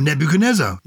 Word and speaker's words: Nebuchadnezzar: Nebuchadnezzar. – Oh, Nebuchadnezzar. You can pronounce it. – Okay. – Nebuchadnezzar. – Nebuchadnezzar. – Nebuchadnezzar. – You don't pronounce the D in it then, Nebuchadnezzar.